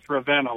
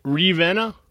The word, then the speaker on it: Ravenna: So why did you a couple of times mispronounce Ravenna like you did? – Ravenna? – Yeah. – I don't, you know what? I've heard a number of people say, Ravenna. And yeah.